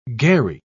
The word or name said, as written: gharry